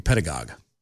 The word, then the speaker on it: pedagogue: Johnson over on Twitter, I believe it's pronounced the same as ideologue or demagogue. So it's supposed to be pedagogue.